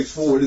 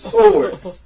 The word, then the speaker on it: sword: I'm not going to say sword. It's sword. W is silent.